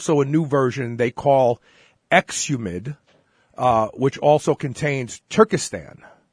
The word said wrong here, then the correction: Turkesterone: Oh, no, Turkesterone.